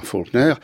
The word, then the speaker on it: Faulkner: Et il a rajouté un U, qui se prononce de la même manière, Faulkner,